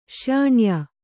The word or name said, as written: ಶ್ಯಾಣ್ಯಾ